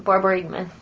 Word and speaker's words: borborygmus: But it's Borborygmus. – You said it right. Borborygmus.